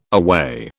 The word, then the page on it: aweigh: British English